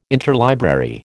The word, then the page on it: interlibrary: American English